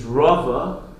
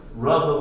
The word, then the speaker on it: Rava: The first, Rabba, is with a base. Rabba versus Rava. Rava with a vase,